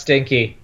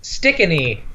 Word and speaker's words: Stikine: S-T-I-N-K-E. – I'm assuming the E is silent. – Maybe I'm spelling it wrong. It's definitely not pronounced Stinky, but Stickin'E. – Ah. – S-T-I-K-I-N-E.